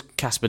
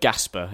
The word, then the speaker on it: Kasper: He calls Kasper Dolberg Gasper.